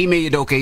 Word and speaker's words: Ime Udoka: Was it Ime Udoka? Okay, Ime Udoka.